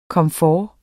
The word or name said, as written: komfort